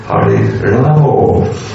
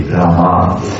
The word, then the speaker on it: Rama: it may happen that devotees chant Hare Ramo, not Hare Rama. That would mean they would imitate the Bengali pronunciation. The Bengalis always say, Ramo! And after an hour they still are there. it should not be this Bengali cultural influence. It must be Rama properly pronounced.